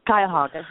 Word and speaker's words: Cuyahoga: She won the 2010 SBA Minority Small Business Champion Award, and she previously worked as director of the, if I'm saying this correctly, you can correct me, Cuyahoga? – Cuyahoga, yes.